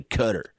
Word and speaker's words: Qatar: Correct you when you say Qatar, and they'd be like, it's actually Qatar.